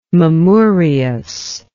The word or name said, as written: mamurius